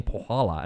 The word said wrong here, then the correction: Polaha: and you're like, it's Palaha, you idiot.